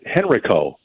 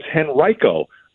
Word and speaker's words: Henrico: And in spring training, when we saw him, I called it Henrico, Virginia. Well, it turns out it's Henrico, Virginia.